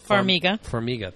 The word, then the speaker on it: Farmiga: Vera, I'm going to mispronounce her name. Farmiga. Farmiga, thank you.